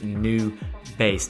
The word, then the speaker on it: Nubass: His name is New Bass, that's spelled N-U-B-A-S-S, and